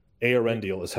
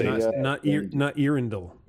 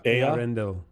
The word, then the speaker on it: Eärendil: Eärendil is how you say not Eärendil. – No. – Eärendil. – Ea, like the word for whole material universe, is called Ea.